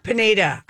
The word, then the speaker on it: Pineda: And I observed this guy named Pinata. I don't know if I'm saying his name right, the pitcher. – Pineda.